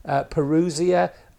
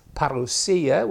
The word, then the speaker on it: parousia: And so this raises for us really what I suppose is our big word of the week, which is, some people pronounce it parousia. Other people would say it is parousia, which I think is probably nearer the Greek pronunciation.